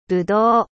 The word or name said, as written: ぶどう